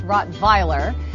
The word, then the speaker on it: Rottweiler: The Rottweiler is an amazing dog, and actually, if you want to be really technical, because it is a German breed, the W is pronounced as a V, like Victor, so it's Rottweiler.